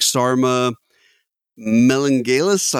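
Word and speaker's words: Sarma Melngailis: But I mean, essentially, you've got the main character, so to speak, Sarma Melengalis. I'm probably butchering her last name. – Nope, you nailed it.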